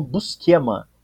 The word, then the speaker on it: Buscema: Porque tem, tem alguns americanos que falam Busquema. Né? Então acho que varia.